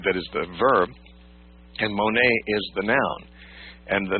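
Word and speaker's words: mone (μονή): We would spell it M-O-N-E, mone.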